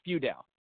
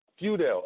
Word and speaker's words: Feudale: It was very close. It was close. So it's Feudale. – Feudale. Okay, that's perfect. Perfect.